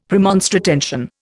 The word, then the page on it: Premonstratensian: Indian